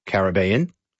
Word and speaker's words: Caribbean: Like we had Caribbean. Yeah. Or as Matthew McConaughey would say, Caribbean.